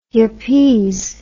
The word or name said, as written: yuppese